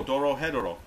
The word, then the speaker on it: Dorohedoro: Oh, Dorohedoro.